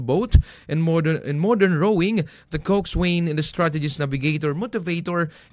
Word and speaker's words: coxswain: Ang pagbasa po ng cox ay coxswain, pero pag pronounce po nito ay Coxswain.